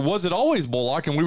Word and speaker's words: Bullock: It was always Bullock. I was just letting people usually pronounce it how they want to pronounce it. I mean, if you're from the country, but I mean, my family is from the country, so the younger people in my family usually pronounce it Bullock.